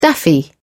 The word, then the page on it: daffy: British English